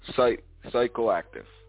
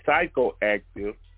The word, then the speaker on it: psychoactive: Okay, alcohol, sometimes referred to by the chemical name ethanol, is a physical active drug. That one is psychoactive. – Okay, the P is silent. The P is silent. Okay, psychoactive drug that is the active ingredient in drinks such as beer, wine, and distilled spirits, hard liquor.